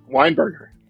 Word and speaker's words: Weinberger: Weinberger.